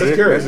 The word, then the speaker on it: Keurig: K-E-U-R-G. – Yeah, that's Keurig. Yeah, that's Keurig. Keurig. – You pronounced it right.